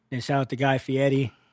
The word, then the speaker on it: Fieri: You gotta say Fieri. If you say Fieri, he's like, nobody's saying it right. It's Fieri.